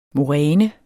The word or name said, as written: moræne